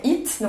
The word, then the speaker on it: Eat: Euh, quand euh, Gilles Lartigot, je ne sais pas si tu le connais, Gilles Lartigot, en fait, il a écrit un livre qui s'appelle It, donc ça s'écrit E-A-T.